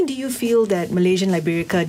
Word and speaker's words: Liberica: Have I butchered that name? No. – Because I'm thinking Liberica.